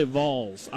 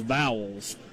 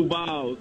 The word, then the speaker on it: Vols: I think you need to say Vols. Because Aaron Hayden, who's from Detroit, Michigan, says Vowels. How do you say it, Coach? – Go Vols.